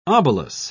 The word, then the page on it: obelus: British English